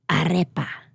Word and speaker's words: Arepa: So I just want to make sure we're saying it right. Arepa, right? – Arepa.